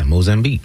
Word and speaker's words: Mozambique: Did I say that right? Mozambique? – Yeah, Mozambique. Mm-hmm.